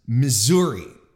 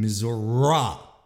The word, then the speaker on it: Missouri: Peter Jennings introduced me to the idea that there is a second way, another way to pronounce Missouri, as in Missouri. If he'd do reports from that particular state, the Show Me State, if I'm not mistaken, he'd call it Missouri.